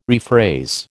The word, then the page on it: rephrase: American English